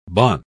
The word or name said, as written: bon